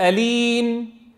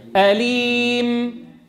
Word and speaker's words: أليم: لا ما منقول أليم، ما بيصير الميم لازم تسكنها. أليم. نستعين.